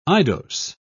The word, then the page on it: eidos: British English